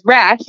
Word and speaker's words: Rasch: But pretty much if you Google my name, which is S-Y-D-N-E-Y, and then my last name is Rasch, it's spelled weird but pronounced rather normally. It's R-A-S-C-H.